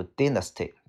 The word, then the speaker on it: dynasty: 下个单词叫做朝代，这个单词可以读 dynasty，也可以读 dynasty，呃呃嗯，还是读 dynasty 会好一些。 OK 啊，好，它的正确发音读 dynasty，叫做朝代，比如说唐朝叫做 Tang Dynasty。